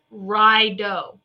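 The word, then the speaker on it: Raido: I'll tell you why, because it looks like an R, and it's, I always want to say radio, but it's Rido. It's the journey. Look at this. Don't you always feel like you're going into battle every day? Okay. It's Rido. Rido is the pronunciation of Rido.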